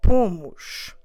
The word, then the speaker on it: pomos: So we put, we just need to say nós pomos. Nós pomos. Como se escreve pomos? P O M O S. Pomos.